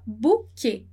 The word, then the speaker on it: bouquet: Muito bem, então como eu já falei, né, o S no final de palavra a gente não vai pronunciar e nem o T. Então a gente vai falar bouquet. Bouquet, como em português.